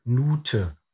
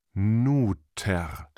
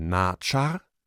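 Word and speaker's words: nṯr: Und da können wir dann lesen, dass sie das Wort Gott als Nute ausgesprochen haben. – Bei dem Fahnensymbol, das die Konsonanten N, T, R enthält, kommen also die Vokale U und Ö dazu. Nuter. – Das R ist dabei verloren gegangen im Laufe der Sprachgeschichte. – Im Laufe der Zeit ändern sich Sprache und Aussprache. Fachleute vermuten anhand von Indizien, dass etwa das Wort für Gott innerhalb von 3000 Jahren verschieden ausgesprochen wurde. Mal Natschar, später Nate, dann erst Nute.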